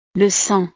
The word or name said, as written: le sang